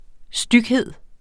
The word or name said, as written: styghed